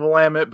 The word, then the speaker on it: Willamette: That was technically a correct pronunciation of Willamette, but it felt wrong in spirit for some reason.